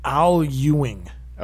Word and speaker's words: Al Ewing: Al Ewing.